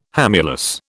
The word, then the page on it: hamulus: British English